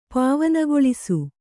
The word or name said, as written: ಪಾವನಗೊಳಿಸು